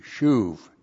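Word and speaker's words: shuv (שוב): Now we have the verb shava. Or not shava, the verb shuv, excuse me. S-H long U-B, shuv.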